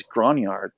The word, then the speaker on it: Grognard: And I was listening to that, and the one guy on there said that because it's French for an old soldier. – Aha. – So the pronunciation, I guess, is Grognard.